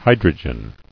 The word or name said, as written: Hydrogenous